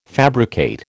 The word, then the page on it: fabricate: American English